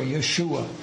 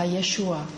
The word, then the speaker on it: Yeshua: So I knew in Hebrew it's Joshua, Yeshua. – A v hebrejštině se říká Yeshua.